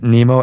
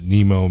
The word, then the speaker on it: Nimmo: I don't know if you pronounce it Nemo, N-I-M-M-O.